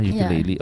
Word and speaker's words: ukulele: So your instrument of choice is for the titos who have no knowledge on musical instrument, this is a ukulele. – Yeah. – Is that how you pronounce it, ba? Ukulele.